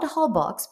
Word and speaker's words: Holbox: Holbox, my last time in Mexico in 2019, before this trip, I visited Holbox Island, spelled Holbox, but pronounced Holbox, on an Airbnb adventure.